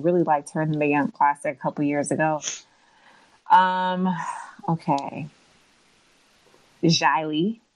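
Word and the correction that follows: Xia Li: Am I saying that right? – I would say Shea, but I don't. – Shea. X-I-A Lee.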